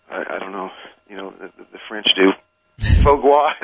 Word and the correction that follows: foie gras: is that how you pronounce it? – Yeah, foie gras, yeah.